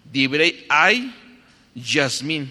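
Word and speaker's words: Dibrey ay, Yasmín (דברי הימים): Se los voy a deletrear. se escribe Di, B de bueno, R-E-Y, Dibrey. Y después la palabra ay, como se escucha, así, A, H, perdón, H-A-Y. Dibrey ay, Yasmín, como el nombre de Yasmín, pero sin Z, con S.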